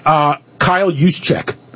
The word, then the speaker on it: Juszczyk: Number two, Kyle Juszczyk.